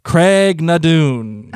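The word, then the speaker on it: Craigh na Dun: How do you say it? – Craig Nadoon. – Oh, look at you. You worked on how to say it this time.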